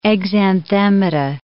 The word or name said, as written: exanthemata